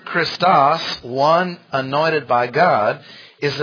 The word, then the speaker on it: Christos: C-H-R-I-S-T-O-S, accent on the last syllable.